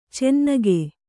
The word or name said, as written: ಚೆನ್ನಿಗ